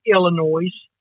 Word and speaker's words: Illinois: Only E's should be silent, not S's. Okay? And I'm just going to – I want to throw that out there. If you are from Illinois, why don't you just pronounce it Illinois? Why put the S on the end if you're not going to say it?